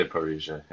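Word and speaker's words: parrhesia: So Parhesia, do you feel, am I pronouncing that right, by the way? – I hope I just say Parhesia. Yeah, that's fine.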